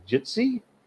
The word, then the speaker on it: Jitsi: This is us starting to record the New Hampshire Independence Town Hall meeting audio taped on, how do you say this thing, Jitsi? – Correct, I guess.